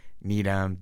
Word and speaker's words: Needham: I was thinking Needham. – Yep, that was what I thought. – Because it ends with H-A-M, but mm-hmm. it's pronounced M.